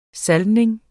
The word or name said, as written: salvning